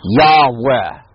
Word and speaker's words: Yahweh: You put a Yod at the beginning of that, and you make the last syllable an eh sound. There's Yahweh. Okay? That's the way you pronounce the name.